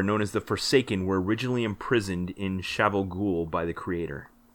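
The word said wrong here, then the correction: Shayol Ghul: It's Sheol Whoops. That's fine. No, no, it's it's a pronunciation thing. And I only know that because I listen to the audiobooks.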